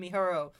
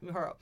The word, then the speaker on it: Mihoro: Having no choice, Kokoro-chan takes Saki to the area, and they discover that adorable teddy bear-like creatures have overcome Mihoro. I said the name wrong. Yeah, Mihoro. The brother, basically. – Yeah, you got it.